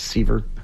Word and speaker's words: Sevier: Well, and if you've ever been to Dollywood or you've been to Sevierville, which is, everyone says, it's Seaver. It looks like Sevier. – I know. – Yeah, I've always said that too. – But it's Sevier. It's pronounced unlike what it looks like.